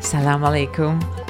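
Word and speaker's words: Salam alaikum (سلام عليكم): Salam alaikum.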